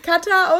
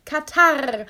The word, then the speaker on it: Kater: Weil anscheinend wird es nicht Kater ausgesprochen, ja, sondern Katar.